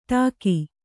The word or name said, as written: ಡಾಕು